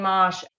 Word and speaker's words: mache: It's grow grain mosh. It's spelled like mache, M-A-C-H-E, but there's no accent. It's just mosh.